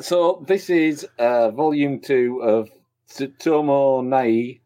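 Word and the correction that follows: Tsutomu Nihei: Apologies for the pronunciation. – Tsutomu Nihe. – Thank you. And this is Opossums, volume two, published by Vertical Comics.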